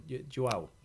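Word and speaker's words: João: På träningen så sa de Joao. Vi ska sätta uttalet. Joao.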